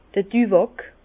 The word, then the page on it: d'r Düwàck: Bas Rhin; Herrlisheim